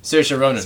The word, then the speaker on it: Saoirse: Saoirse Ronan. Yeah, so you can't get it. – Saoirse Ronan.